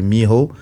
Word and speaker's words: Mijo: Ben, en fait, euh, c'est lui qui a créé le crump avec un autre gars qui s'appelle Miho. Enfin, en, ça s'écrit M-I-J-O.